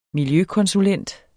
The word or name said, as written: miljøkonsulent